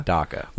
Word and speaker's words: Dhaka: Dhaka. – Was restrained. – Not like D-A-C-A. Like D-H-A-K-A.